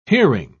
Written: hearing

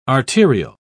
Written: arterial

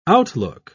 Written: outlook

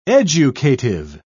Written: educative

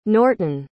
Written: nortin

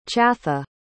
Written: chatha

